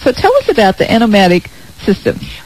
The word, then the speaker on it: Enomatic: Okay, well, your store is beautiful, and I saw something in your store that I've never seen anywhere else, and I'm probably saying it wrong. Is it Enomatic system? Enomatic system? – Enomatic. – Enomatic right.